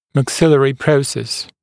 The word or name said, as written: maxillary process